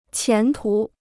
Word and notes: 前途: qián tú

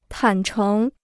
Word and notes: 坦诚: tǎn chéng

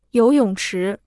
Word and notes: 游泳池: yóu yǒng chí

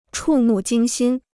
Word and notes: 触目惊心: chù mù jīng xīn

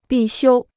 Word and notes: 必修: bì xiū

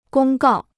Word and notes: 公告: gōng gào

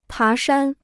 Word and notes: 爬山: pá shān

